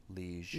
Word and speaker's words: Lige: That's, like, after she, you know, shacks up with, how do you say his name? Liege? – I was reading it as Liege. – That sounds right. – L-I-G-E, Liege.